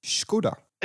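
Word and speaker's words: Škoda: The women called it Skoda. And apparently that's how you pronounce it. Skoda.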